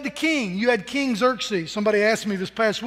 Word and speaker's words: Xerxes: How do you pronounce this king's name? Well, you can just call him King X if you want to. But it's called Xerxes, King Xerxes. And his part in the story was that he had a queen.